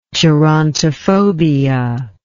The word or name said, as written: gerontophobia